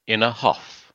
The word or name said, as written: in a huff